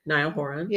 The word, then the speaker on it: Niall Horan: By Niall Horan. Is that his name? – Yeah. Niall Horan.